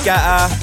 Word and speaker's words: Guetta: Done two for Chainsmokers, two for David Guetta, not Guetta, as someone pointed out, I'd wrongly pronounced it last month.